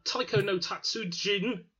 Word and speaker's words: Taiko no Tatsujin: Uh, Taiko no Tatsujin, I think I pronounced that correctly.